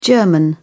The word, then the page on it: german: British English